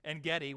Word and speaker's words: En-Gedi: We actually hiked the Valley of En-Gedi. Did I say that right? I think so. En-Gedi,